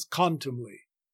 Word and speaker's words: contumely: We would have way too many syllables if we gave full weight to the word the and gave contumely 4 syllables with the stress on the second syllable as in modern speech. But Shakespeare means us to elide the word the with oppressors and to make contumely in fact 3 syllables with the main stress on the first syllable and another lesser stress on the last.